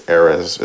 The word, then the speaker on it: Erez: I think it's Erez is how you say it, E-R-E-Z.